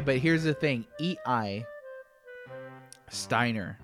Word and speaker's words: Steiner: But so like my favorite character in the game is Steiner, the knight, right? Is that how you pronounced it? – That's how I pronounced it, yeah. – Yeah, And we both agreed it's definitely probably Steiner. I haven't met anybody who's like Steiner or Stainer or something like that, but both of those sound really awkward and strange.